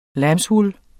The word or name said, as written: lambswool